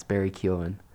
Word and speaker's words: Keoghan: I didn't know how to pronounce his name, so I Googled it this morning. – Wow. – But yeah, it's Barry Keoghan.